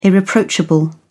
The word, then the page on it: irreproachable: British English